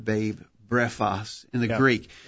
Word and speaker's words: brephos: Spelled with English characters, B-R-E-P-H-O-S, brephos.